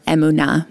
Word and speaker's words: emuna: Amuna, or emuna, more properly pronounced in Hebrew.